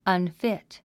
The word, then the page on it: unfit: ʌnfít; アンフィット